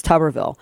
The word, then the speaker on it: Tuberville: I'm sorry, it's still weird to say his name like that because I want to say Tuberville. I've said Tuberville the entire damn time. It's Tuberville. But it's a ridiculous question because it's about the NDAA, which we've talked about at length on the program.